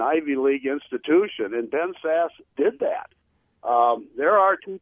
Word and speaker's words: Sasse: Pronounce it Sass. It's Sass. – Spelled S-A-S-S-E. It looks like it could be sassy, but yeah. It's pronounced Sass.